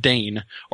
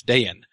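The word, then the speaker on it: Dáin: Um, so when you have somebody whose name is D-A-I-N with an accent over the A, he's doing that to indicate that you're supposed to pronounce that as two syllables. It's not just Dane or Dine. Um, it's two syllables. It's Dayan. Um, and similarly, G L O I N is not gloin, it's glowin.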